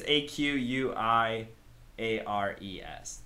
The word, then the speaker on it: Aquiares: Okay, I think it's A Q U I A R E S.